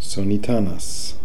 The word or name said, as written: Sonitanis